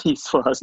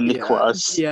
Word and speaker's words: Niçoise: It's a salad, Niçoise, not a Niçoise.